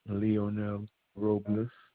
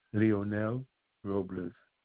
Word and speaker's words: Leonel Robles: Leonel Robles. – You said Leon for the first name? – Leonel Robles. – Could you spell that for me, please? – L-E-O-N-E-L.